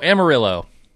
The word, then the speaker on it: Amarillo: So, Amarillo is how you say this.